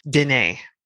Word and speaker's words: Denis: So I'm very happy for it's his name is French. You don't pronounce the S. I'm very happy for Denis.